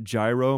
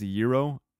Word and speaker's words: gyro: Most people pronounce it gyro, but it's pronounced gyro.